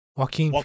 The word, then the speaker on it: Joaquin: Jaquan? – Joaquin Phoenix.